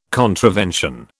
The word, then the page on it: contravention: British English